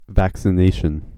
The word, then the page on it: vaccination: American English